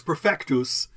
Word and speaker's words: perfectus: Oh, that's perfectus.